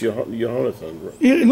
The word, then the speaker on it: Jehonathan: They spell it with the H in Hebrew, mm-hmm. but they don't change the way it's spelled in English. If you didn't look at the Hebrew, you wouldn't know that it's Jehonathan.